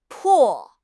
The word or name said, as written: po4